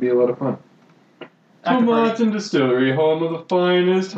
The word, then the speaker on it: Tomatin: Established in 1897, Tomatin, to rhyme with satin.